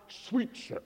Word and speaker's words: Sweitzer: Now it's spelled S-W-E-I-T-Z-E-R, but it's pronounced Sweetser.